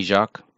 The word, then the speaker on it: Jacques: Yeah, you got it, actually. You're really right. Jenny Jacques. – Okay. – Because the S is silent.